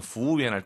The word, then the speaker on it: 服务员: 재밌는 건 이 저만의 팁을 드리면 服务员 할 때 그 우를 발음하지 말아보세요. 服务员 해도 괜찮아요. 그죠? – 오, 好像是中国人一样. – 그죠? 그러니까 굳이 服务员 이럴 필요 없다고요. – 네, 服务员. – 아, 그냥 이렇게 W를 빼도 됩니다.